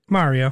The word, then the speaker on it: Mario: It's not Mary with an O. – Yeah, Mario. That's a good point. Yeah, I never really thought about that. How does he say it in the movie? – Mario.